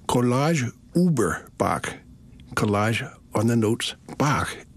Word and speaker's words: Bach: Bach, but the musical tones, B-A-C-H, or to pronounce them in German, B-A-C-H. B-A in German is not B, it's B-flat. So it's B-A-C-H.